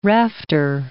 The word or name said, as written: rafter